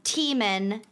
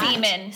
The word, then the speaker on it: Tiemann: His name, again, is Charles Tiemann. – Not Seaman. – Seaman. – Okay. Got it. – Someone misheard me. – It was all of us in the world. – Tiemann is spelled T-I-E-M-A-N. M-A-N-N? – And Seaman is spelled S-E-M-E-N. – It's T-I-E-M-A-N-N.